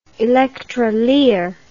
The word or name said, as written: electrolier